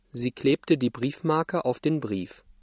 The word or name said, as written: Briefmarke